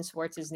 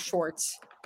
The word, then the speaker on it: Swartz: I'm trying to correct the pronunciation and the spelling of Austin Swartz's name. It's Swartz, not Schwartz. It's Swartz.